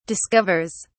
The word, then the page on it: discovers: British English